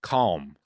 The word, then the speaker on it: calm: It is a weird word. Calm. We can all agree. – Yeah, it's that weird L. – That's yeah, do you pronounce L. the L? – Is it calm? – Calm. – Is it calm? – It'd be better if it was C-A-H-M, maybe.